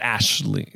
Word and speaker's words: Aisling: Yeah, I believe it's you pronounced Ashley. Like Aisling is like Ashley.